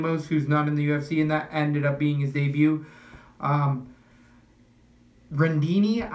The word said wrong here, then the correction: Rinaldi: how am I pronouncing that? I feel like I get it wrong. – Jordan Rinaldi versus Alvaro Herrera.